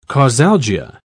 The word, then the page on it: causalgia: British English